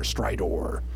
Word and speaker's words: Stridor: And I say that, I pronounce it that way because they spell it not with an E-R, but an O-R, Stridor.